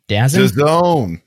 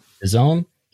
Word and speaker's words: DAZN: He worked formerly at, how do you say this company? DAZN? DAZN? – DAZN. – DAZN. He formerly worked at DAZN under John Skipper, who used to be in charge of ESPN.